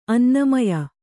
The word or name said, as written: ಅನ್ನಮಯ